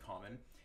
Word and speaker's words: COMMXN: That's one thing I always get asked now that I went through the rebrand is that like how do you pronounce, you know, C-O-M-M-X-N? And so we pronounce it common.